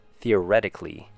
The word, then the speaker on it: theoretically: The, long E sound. Short U sound. Re, short E sound. T, short U sound. And a blending of the letter K and Lee. Theoretically.